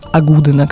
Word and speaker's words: агудина: И произносится оно как «агудина» как раз.